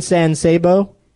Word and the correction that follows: San Saba: San Saba?